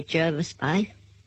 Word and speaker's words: Jervis: And they say Jervis Bay. – I think they do say, I think locals say Jervis Bay, Yes. – but many people say, I'm going to holiday at Jarvis Bay. – Yeah, but it's spelt Jervis.